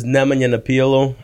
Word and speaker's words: Nemo Napijalo: Full name is Nemo Napijalo.